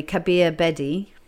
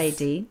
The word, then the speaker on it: Kabir Bedi: And your second husband, I may be pronouncing it wrongly, Kabir Bedi. – Yes. – Bedi. – Bedi, very good.